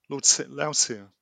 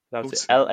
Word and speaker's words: Lautier: The second short film that we watched and are now reviewing was called Lautier, which is that how you'd pronounce that, or Lautier? – Lautier. – Lautier.